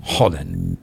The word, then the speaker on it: Haaland: Pero en realidad es Haaland. Es el nombre del jugador, o así se pronuncia correctamente el nombre del jugador, que se come 6,000 calorías diarias el man.